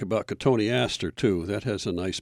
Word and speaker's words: Cotoneaster: Spell it Cotton Easter. That's Cotoneaster.